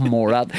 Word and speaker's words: Morat: The leader of this group, if you want to call them that, like the main pirate, he's called, it rhymes with Borat, I think it's Morat. That's how I remembered it when I was doing the research. He's called Morat.